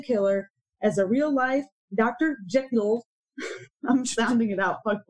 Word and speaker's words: Jekyll: Jekyll. Saying it right. You tell me to sound it out. – It's Jekyll.